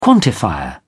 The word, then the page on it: quantifier: British English